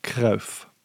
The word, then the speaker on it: Cruyff: Johan Cruyff.